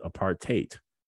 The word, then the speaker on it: apartheid: Um, some Africans call it apartheid to rhyme with hate.